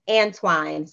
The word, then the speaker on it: Antwine: So Phyla, F like flower, I-L-A, and then the last name is Antwine. So it's Ant, A-N-T, and Wine, W-I-N-E.